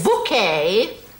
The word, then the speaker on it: Bucket: Bouquet. – That's spelled B-U-C-K-E-T. – Oh, yes, we have a bucket. – Pronounced bouquet, dear.